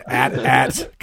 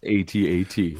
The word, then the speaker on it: AT-AT: you know, it's actually pronounced A T A T. Well, fuck oh, that person, up, and fuck everybody else who says it, because as long as those movies have been out, people have been pronouncing it at at.